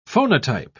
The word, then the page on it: phonotype: British English